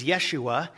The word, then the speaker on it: Yeshua: And that name people don't always realize, but in Hebrew it was Yeshua, and we pronounce it Jesus, but it is written Yeshua, and it has a meaning.